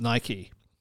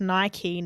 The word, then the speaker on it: Nike: I want to just catch up on something we were talking about last week on Friday, the pronunciation of Nike, which I said was Nike and you said was Nike. – Yeah, so I actually did a quick Google on this. – Nice. – And it turns out their chairman, Philip Knight, has confirmed that it's Nike, not Nike.